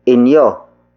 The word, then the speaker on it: inyo: who happens to be inyo. Inyo. Inyo. Inyo is spelled as I-N-Y-O.